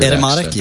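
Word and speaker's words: Etymotic Research: It's Edemotic Research. Is that how you pronounce it? They have a weird Edemotic, yep, that's it.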